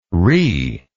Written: rhe

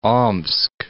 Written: Omsk

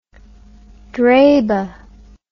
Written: draba

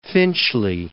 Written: Finchley

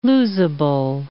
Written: losable

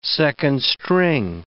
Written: second-string

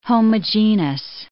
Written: homogenous

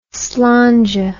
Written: slainte

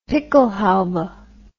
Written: pickelhaube

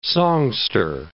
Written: songster

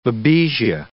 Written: babesia

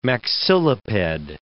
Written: maxilliped

